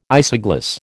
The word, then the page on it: isogloss: American English